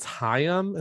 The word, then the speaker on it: Haim: I think it's Haim. It's H-A-I-M.